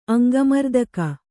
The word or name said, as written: ಅಂಗಮರ್ದಕ